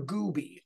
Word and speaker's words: Gooby: In 2012, one of the bobbleheads that the Bradenton Marauders, a Pittsburgh Pirates affiliate, gave away that season was one of Trevor Gooby. I will spell that for you. It is G-O-O-B-Y, Gooby, who was at the time the Pirates director of Florida operations.